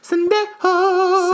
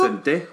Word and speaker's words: Sendejo: Safety, Andrew Sendejo. – Sendejo. – Sendejo.